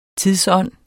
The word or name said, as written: tidsånd